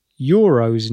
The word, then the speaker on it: Euro: So you can hear the pronunciation of euro is quite different than in English, and they don't bother adding an S to the end for euros. It simply is euro. And you do not pronounce in German the sort of y sound that we've put in front of euro in English.